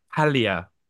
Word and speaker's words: Paglia: That's what Camille, Paglia. You don't pronounce the G.